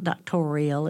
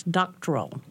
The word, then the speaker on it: doctoral: It's not doctorial, it's doctoral.